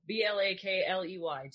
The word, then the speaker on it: Blakley: Blakley. To steal a joke from my partner, back in the day, I must have had an illiterate ancestor, because my name is not spelled how it's pronounced.